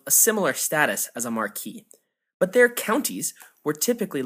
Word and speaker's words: Marquis: Incidentally, I am aware that the title Marquis is pronounced in the UK as Marquis, but since I took French in high school, I prefer the French pronunciation, so that's what I'll be going with.